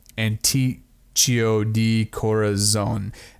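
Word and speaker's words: anticucho de corazón: And the street food, for anybody who wanted to know, it's like pronounced, it's beef heart shish kebabs, but it's like Anticho de Corazon.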